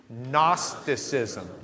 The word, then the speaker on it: Gnosticism: Gnosticism. The G is silent. Do you know why? For the same reason the K is silent in Kno. G-N-O was their K-N-O, from which we get Kno, because they gnu about it back then.